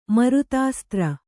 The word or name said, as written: ಮರುತಾಸ್ತ್ರ